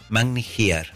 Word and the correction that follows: Magni Ear: Magni Ear. – Bueno, yo pronuncio a mi forma. – Ah, con razón, porque here es otra cosa, es de aquí. – Ear se escribe.